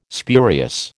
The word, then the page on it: spurious: American English